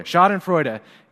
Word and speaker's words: Schadenfreude: And then I talked to Rebecca, who apparently speaks German, and she told me that my R was a little weak, but that it was going to be okay. And so all of us together, we can just say Schadenfreude.